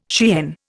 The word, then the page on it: Cheyenne: Indian